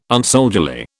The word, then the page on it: unsoldierly: British English